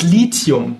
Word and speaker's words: Lithium: Was ist denn eigentlich richtig? Lithium oder Lithium? Genau, es ist Lithium, weil es kommt von dem altgriechischen Wort Lithos, was so viel wie Stein bedeutet. Und ähm, ich bin selbst kein Chemiker, aber mir wurde gesagt, wenn immer jemand Lithium statt Lithium sagt, dann weint irgendwo ein Chemiker.